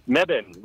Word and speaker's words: Mebane: I'm in a place called Mebane. – You're going to do what? You're in a place called Mebane? – Mebane, right.